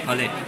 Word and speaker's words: Palais: Also der hat dann hier ein Palais, spricht man das aus, ne? – Palais.